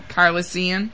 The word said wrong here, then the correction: Calrissian: Calrissian. – Calrissian. – Calrissian. – Calrissian. – Calrissian. Yes.